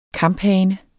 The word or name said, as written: KAMPHANERNES